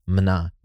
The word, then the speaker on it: minas: But we did just confirm, both through Lagos and through YouTube, that M-I-N-A-S is pronounced mana. Really, it's pronounced na.